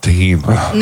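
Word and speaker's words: Thinh: T-H-I-N-H. I don't know if I'm pronouncing that correctly. – It's pronounced Tahim.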